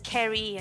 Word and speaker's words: Ceri: We've got plenty of time to work with names, and I always write it down and get my red pen out, mark out that hard C so they get it as Ceri,